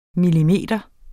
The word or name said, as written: millimeter